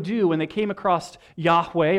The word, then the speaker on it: Yahweh: In English, the letters are YHWH. Probably pronounced Yahweh. You may have heard it mispronounced as Jehovah in the past.